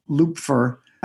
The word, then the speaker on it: Lupfer: Lupfer, and the last name is spelled L U P F E R dot com.